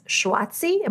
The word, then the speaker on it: schwazy: On your website, you describe your lifestyle as schwazy. Am I pronouncing that correctly? How do you say that word? – Schwazy. – Schwazy. Okay.